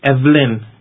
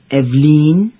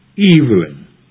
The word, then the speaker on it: Evelyn: It is spelled E-V-E-L-Y-N. Evelyn. – Or is it Evelyn? – Neither, I'm afraid. Try to say Evelyn. Evelyn. Eve, as in Adam and Eve. And then add Lynn, as in lintel.